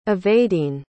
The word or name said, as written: evadine